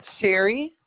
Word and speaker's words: Sherry: Sherry Fistry.